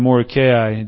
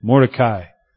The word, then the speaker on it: Mordecai: Esther, we've been talking about Esther and Mordecai just in some conversation. Mordecai, I heard, is better. Mordecai is a very American way to pronounce it. Right? We like our syllables.